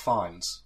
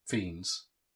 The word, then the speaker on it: Fiennes: I'd call him Ralph Fiennes. But then apparently it's Ralph Fiennes.